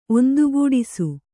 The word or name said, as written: ಒಂದುಗೂಡಿಸು